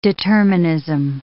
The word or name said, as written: determinism